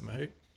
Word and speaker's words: mahout: Like I read somewhere that so they get tortured for you know a few weeks, and is that how you pronounce it, Mahout? – Yeah, exactly.